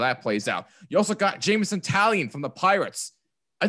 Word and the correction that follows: Taillon: Sorry if I'm saying that incorrectly, by the way. It's Jameson Taillon. Taillon, thank you.